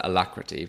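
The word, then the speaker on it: Alacritty: So I pronounce it alacrity, but I don't want to get in the way of any other pronunciations.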